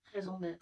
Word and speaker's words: raison d'être: Is that the correct pronunciation? – What? – Raison d'être. There it is.